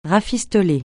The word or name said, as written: rafistoler